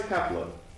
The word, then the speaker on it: Kefla: Kali. – Kali Power. – No. He fights Kefla.